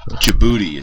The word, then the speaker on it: Djibouti: Djibouti is how you say that one. – Djibouti? – Yeah, silent D.